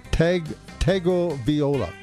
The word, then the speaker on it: Tagovailoa: And how do you pronounce his last name? Taglia. – That's it. – Tagovailoa. – That's it. I think you got it right.